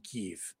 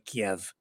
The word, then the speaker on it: Kyiv: And there are reports that they're setting up basically a ring around the capital in Kyiv, or Kiev, depending on whatever era you want to go back to choose how to pronounce that.